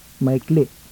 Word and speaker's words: maikli: The acute glottal, that is, a combination of an acute and glottalic accent on the last syllable. Pronounce the word for short. – Maikli.